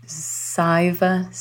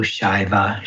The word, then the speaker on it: Shaiva: So when the S has a little accent over it in Sanskrit, it's pronounced as an SH. So it's actually Nandu Shaiva Shakta Tantra.